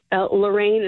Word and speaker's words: Lorraine: Lorraine is spelled LORRAINE.